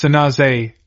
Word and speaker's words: San Jose: San Francisco and Oakland, Santa Clara, San Jose, which is pronounced Sanaze, are large, influential cities.